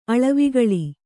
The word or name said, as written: ಅಳವಿಗಳಿ